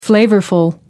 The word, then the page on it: flavorful: American English